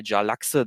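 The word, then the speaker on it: Jarlaxle: unter anderem zum Beispiel Jalaxe, den berühmten Drow-Söldner-Hauptmann, der bekannt ist aus den Driste-Urden-Romanen von R.A.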